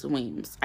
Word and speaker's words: Weems: Okay, fun fact, my maiden name is Weems, Idalis Weems. Spelled the exact same, pronounced the same,